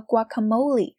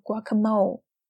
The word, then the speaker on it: guacamole: wow, this uh this guacamole has got a kick. I'm gonna run to the little girl's room. You guys go ahead. I might be a while. – 我们来看啊，这 Gabrielle 说：“ 嗯，this guacamole has got a kick。” 那这个 guacamole 呢，就是牛油果酱。大家注意，这个 guacamole 它是西班牙语，所以呢，我们在念的时候不要念作了 guacamole。